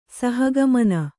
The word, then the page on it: ಸಹಗಮನ: saha gamana